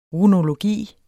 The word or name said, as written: runologi